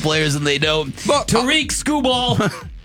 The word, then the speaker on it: Tarik Skubal: It's Tarik Skubal. Not Tarik. Not Tarik. Not Skubal. Not Skrubal. – Yeah. – Tarik Skubal. – I think four syllables.